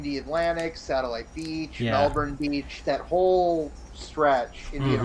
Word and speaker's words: Melbourne: Or is it Burn? Is it Burn where you don't pronounce the O? – Correct. – Really? – Melbourne. – That's how they pronounce it? – It's Melbourne.